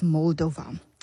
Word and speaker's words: Moldova: Elalizwe mphulaphuli, the correct pronunciation of it is Moldova.